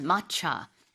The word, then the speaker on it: matcha: 마차가 영어식 표현인데 우리는 이제 말차라고 하잖아요. 영어로는 matcha, M-A-T-C-H-A라고 하는데 이거 이제 진한 녹차죠.